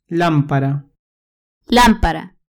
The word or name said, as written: lampara